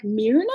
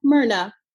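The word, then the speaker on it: Mirna: for some reason, people look at my name and they're like, Myrna? And I'm like, no, Myrna. – I just want people to know it's spelled M-I-R.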